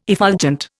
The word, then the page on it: effulgent: Indian